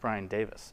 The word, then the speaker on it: Davies: And his name is actually pronounced Brian Davis.